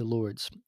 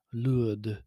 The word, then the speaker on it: Lourdes: Uh, Mary really saved me for, from my fear and doubt on a pilgrimage I made to Lourdes, or uh, as I should more accurately pronounce it, Lourdes.